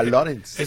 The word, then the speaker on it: Lawrence: Se escribe Lawrence, pero se pronuncia Lawrence.